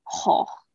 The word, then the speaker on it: Gogh: I think during our like sixth grade math class, and then all of us would just go around being like Vincent Van Gogh. – Oh man. – But yeah, apparently the V's are pronounced as F's and it's not go, it's ho.